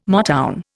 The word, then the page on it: Motown: Indian